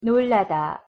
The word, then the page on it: 놀라다: Korean